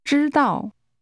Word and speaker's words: 知道: 知道。 – This is pronounced with a high level followed by a falling tone.